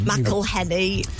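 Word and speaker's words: McElhenney: I'm going to always say his name wrong. – McElhenny. – Rob McElhenny. Bought them and now they live in his garden.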